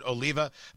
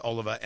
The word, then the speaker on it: Oliva: Sometimes people pronounce it Oliva, but those people are just terrible. I believe it is pronounced Oliva, and that's what I'm going to ask you to do.